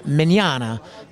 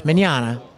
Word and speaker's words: Mañana: It's pronounced Mañana, like tomorrow in Spanish. Mañana.